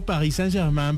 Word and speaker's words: Paris Saint-Germain: Paris Saint-Germain va a jugar contra Arsenal.